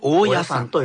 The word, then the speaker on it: 大屋山: 大の山と大きな野原の山と書いて、はい、大屋山と読みます。